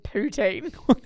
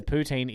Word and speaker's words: poutine: Just the way you said poutine. – That's how you say it. In Canada, poutine is incredible. That's how it's said.